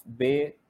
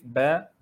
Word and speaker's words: ba: So they say, Alif, Be, Te, Se. The right way of pronouncing these Arabic letters is Alif, Ba, Ta, Sa. This is how you pronounce it in Arabic.